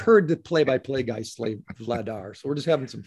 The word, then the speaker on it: Vladar: Vladar.